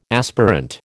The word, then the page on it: aspirant: American English